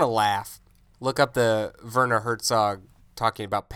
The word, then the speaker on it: Werner: Yeah, Werner, I think, is how it's pronounced.